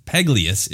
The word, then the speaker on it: Pagalies: It's Teemto, T-E-E-M-T-O, and then Paglius is how I'm saying it. P-A-G-A-L-I-E-S. – Oh, trust me, that comes up.